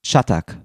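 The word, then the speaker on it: Çatak: Und ich habe jetzt extra nochmal nachgefragt, wie er korrekt ausgesprochen wird. Und es ist, glaube ich, Ilka Chatak. – Mhm. – Das C hat unten so ein Häkchen.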